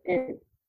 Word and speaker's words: Baumann: So my name, let me actually spell it, because it's so Laura and my last name Baumann.